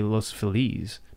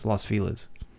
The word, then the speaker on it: Los Feliz: I would originally pronounce it like the correct Spanish pronunciation, which would be Los Feliz, but that's not at all. Because it's, I mean, you pronounce Los Los, so it's Los Feliz. And Mexican people cringe.